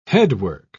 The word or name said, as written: headwork